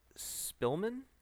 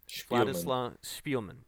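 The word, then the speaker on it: Szpilman: Vladislav Spielman? – Spielman. – Okay. Almost. It was close.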